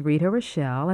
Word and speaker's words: Rita Rochelle: My name is Rita Rochelle, and that's spelled R-I-T-A-R-O-C-H-E-L-L-E.